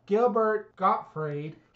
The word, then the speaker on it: Gottfried: I can never say his name. Gilbert Gottfried. – He finally gets it right.